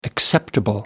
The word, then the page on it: acceptable: American English